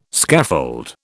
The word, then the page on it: scaffold: British English